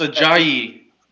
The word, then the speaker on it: Ajayi: How do you say this guy's last name? – Steven. A J E. – It's Ajayi. – It's Ajayi. – LP. – No, it's Ajayi.